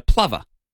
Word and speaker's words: plover: Plover pronounced like lover with a P in front. So plover. Oh.